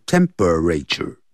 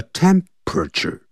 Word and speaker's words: temperature: 量脉搏用 feel 这个字，F-E-E-L, feel, feel one's pulse, P-U-L-S-E. Take one's temperature, 就这么说的。这个 temperature 不要念成四个音节，不要念成 temperature，叫 temperature，那个。 P-E 那个 E 也是不发音的 temperature 念快 temperatures 啊，